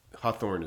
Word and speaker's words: Hawthorne: Hawthorne should have learned from his, Hawthorne is how you say his name, right? – Yeah. Nathaniel Hawthorne? – Oh yeah. Nathaniel Hawthorne changed the spelling of his name, but didn't change the pronunciation.